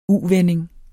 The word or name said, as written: U-vending